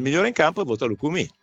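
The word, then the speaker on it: Lucumí: Camillo mi dice che si dice Lukumi con l'accento sulle I, te lo dico io Ive, Camillo dice Lukumi.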